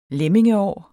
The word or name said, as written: lemmingeår